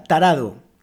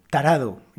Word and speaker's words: tarado: Bien, otra palabra, la segunda, tarado. Tarado. Y esta, cuidado que es una R suave, tarado. Es un poco difícil de pronunciar para algunas personas.